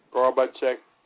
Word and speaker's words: Gorbachev: Gorbachev.